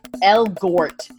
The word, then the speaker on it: Elgort: Elgort.